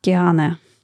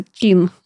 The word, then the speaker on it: Keane: Но я упорно произносила название этой группы как Киана, потому что, ну, как я могла понять, что вот это читается Кин.